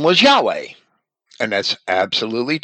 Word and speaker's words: Yahweh: Recent scholars, accordingly, with but few exceptions, are agreed that the ancient pronunciation of the name was Yahweh, the way we spell it in Christian Identity. The first H sounded at the end of the syllable.